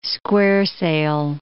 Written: square sail

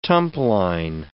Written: tumpline

